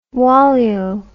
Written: walyo

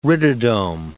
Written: rhytidome

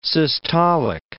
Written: systolic

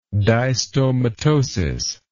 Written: distomatosis

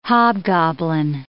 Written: hobgoblin

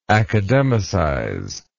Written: academicize

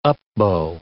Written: up-bow

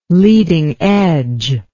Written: leading edge

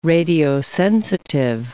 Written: radiosensitive

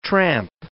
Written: tramp

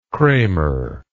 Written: kramer

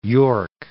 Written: Cape York Peninsula

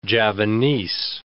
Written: Javanese

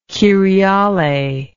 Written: kyriale

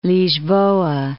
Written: Lisboa

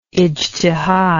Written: ijtihad

